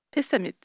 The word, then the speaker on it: Pessamit: Pessamit, c'était presque Pessamit.